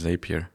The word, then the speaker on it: Zapier: Du meinst Zapier. Genau. Zapier.